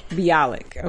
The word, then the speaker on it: Bialik: Bialik.